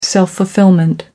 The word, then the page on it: self fulfilment: American English